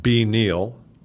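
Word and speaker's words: BNeale: Brian's is at B Neal. – It's B-N-E-A-L-E. – Right. – Silent E.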